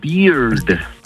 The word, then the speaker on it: beard: Porque barba en inglés se pronuncia beard, termina con D al final.